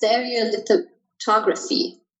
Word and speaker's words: stereolithography: And so the printer uses SLA, it's stereolithography. I said it correctly.